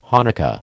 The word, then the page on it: Hanukkah: American English